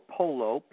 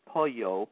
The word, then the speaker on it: pollo: Popular meats are a veal or vitello, a pork, porco, looking pretty easy, chicken or pollo, P-O-L-L-O, not to be pronounced like Spanish, which is pollo. They may not understand what you're talking about, so just pronounce all the letters as you do in most Italian words, pollo.